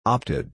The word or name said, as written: opted